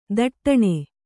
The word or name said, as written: ಥಟ್ಟನೆ